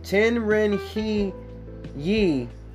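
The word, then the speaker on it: tian ren he yi: R-E-N means human being or the smaller universe. H-E-Y-I means join as one. So, pronouncing this together, Ten Ren He Yi, pronounce T-Y-E-N W-R-E-N H-U-H-W-E-E means the bigger universe and the smaller universe are all one.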